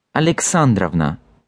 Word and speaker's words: Alexandrovna: You probably also noticed that I pronounce the ending itself as Ovna with clear O sound at the beginning, but in the actual patronymic, this O is turning into an A sound, Alexandrovna, sounding like Avna.